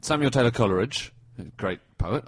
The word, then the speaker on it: Coleridge: And in his own poems, he rhymes it with Polar Ridge, Scholar Ridge, and The Whole Ridge.